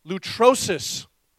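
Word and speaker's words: lutrosis (λύτρωσις): It's pronounced lutrosis. Lutrosis is the Greek word for redemption.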